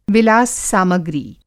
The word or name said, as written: विलास-सामग्री